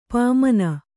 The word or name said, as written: ಪಾಮನ